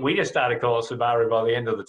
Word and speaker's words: Subaru: it is Subaru, and it's the right way to pronounce it.